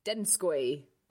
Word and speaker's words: Donskoy: and um, so it's spelt like D O N S K O Y, but apparently it's pronounced Denskoy. Denskoy. Denskoy. So it's pronounced like Dens, not Dons.